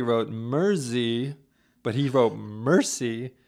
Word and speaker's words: Mercy: Yeah, and he said we're pronouncing it Mercy, M-E-R-C-Y, like Mercy, Mercy, Mercy by Joe Zo and all. So I wrote back, hey man, thanks a lot. Would you mind phonetically spelling that? And then he wrote back in all caps, M-E-R space Z-E-E. – Mer-Z. – Mer-Z. – With a Z, not a S.